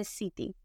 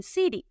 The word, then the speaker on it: city: Y este es un fenómeno peculiar de la pronunciación de los Estados Unidos solamente, que las T's tienen un formato suave, como en agua, water o como en city, city.